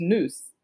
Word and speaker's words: nous: It's just it's just nous. It's pronounced N O O C E, and I was like, okay, what is what is this word?